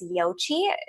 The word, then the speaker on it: Yoqi: And then you've probably seen me post about this, but another resource that I really love is Yochi. It's spelled Y-O-Q-I.